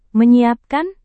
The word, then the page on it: menyiapkan: Indonesian